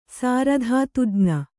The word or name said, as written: ಸಾರಧಾತುಜ್ಞ